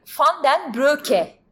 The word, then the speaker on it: Broeke: Adı Peter van den Broeke, Broeke ya da Broke. Broke. Broke. Broeke. – Bir daha söyle bakayım ne güzel söyledim. – Peter van den Broke diye e, telaffuz ediliyor.